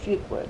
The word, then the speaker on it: Fuqua: Delcy, D-E-L-S-I-E, F-U-Q-U-A, Fuqua, Delcy Fuqua.